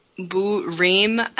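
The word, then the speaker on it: bouts-rimés: Burim, I think it's pronounced in Italian, spelled B-O-U-T-S-R-I-M accent E-S.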